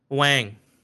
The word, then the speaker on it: Quang: My middle name is Quang, right? Quang. That's how it's pronounced phonetically. But it's pronounced Wang. Q is like right.